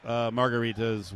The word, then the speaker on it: margarita: I have grown to love margaritas with... – Margaritas. Say it like the pizza. Margarita.